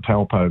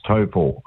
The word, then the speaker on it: Taupo: Some people call it Taupo, but it's the New Zealand pronunciation is Taupo.